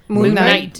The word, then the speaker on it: Moon Knight: Moon Knight.